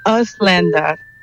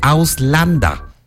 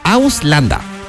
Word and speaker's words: Ausländer: Ausländer. – Casi. Me da permiso de echarle la manita con la pronunciación. – Ok. – A ver, se dice Ausländer. – Oh. – Ausländer.